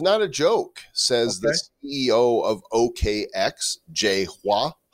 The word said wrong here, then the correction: Hao: Jay Hao. – I don't know how to pronounce. – It's H-A-O. That would be how.